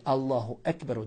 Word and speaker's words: Allahu Ekber: Ispravno je Allahu Ekber od riječi Kebir, Allah je najveći.